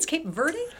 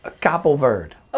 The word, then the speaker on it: Cabo Verde: Is that the, are we saying it right? It's Cape Verde? – Cabo Verde. – Oh, no, we weren't even close, no.